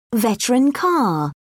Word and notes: veteran car: British English

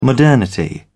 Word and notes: modernity: British English

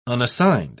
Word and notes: unassigned: British English

